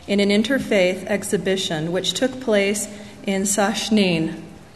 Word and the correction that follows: Sakhnin: I didn't pronounce it correctly, an Arab village. During the months of getting the exhibition ready, we spent many weekends in Sakhnin, and I met many wonderful Arab people for the first time in my life.